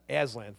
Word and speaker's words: Aslan: Somebody say it for me. Aslan, thank you.